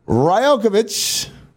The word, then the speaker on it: Rajaković: Yes, head coach of the Toronto Raptors, Darko Rajkovic. Think I got that right. – You did. I just looked up how you pronounce it before we started.